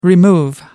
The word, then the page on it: remove: American English